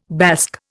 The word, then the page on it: Basque: Indian